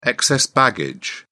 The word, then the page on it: excess baggage: British English